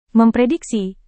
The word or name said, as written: memprediksi